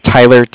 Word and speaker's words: Tyler: My name is Tyler, T-Y-L-E-R. – Okay. – And I'm calling from Cardiff. It's spelled C-A-R-D-I-F-F, as in Frank Frank.